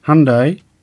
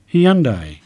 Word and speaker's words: Hyundai: However, British English speakers are likely to use three syllables, saying the name as Hyundai. The Koreans told me you pronounce it like Sunday, but with an H, so I'll stick with what they've told me.